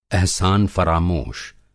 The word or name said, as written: एहसान फ़रामोश